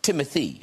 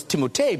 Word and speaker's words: Timothée: It's pronounced Timothy. – It's whatever you'd like. – I always thought, I don't know if you guys, I thought it was Timothée because of the double E's at the end.